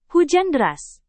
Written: hujan deras

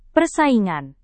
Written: persaingan

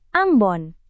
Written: Ambon

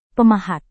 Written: pemahat

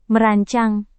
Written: merancang

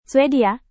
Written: swedia

